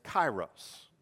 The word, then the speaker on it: kairos: The Greek word that was used in the New Testament for this verse, for opportunity, the Greek word is kairos. It's spelled K-A-I-R-O-S, kairos.